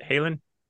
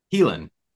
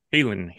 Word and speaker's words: Haelan: How do I pronounce it? Halen? – Helen. – Helan, Helan, the E. Okay. Helan, yeah. It's old old English.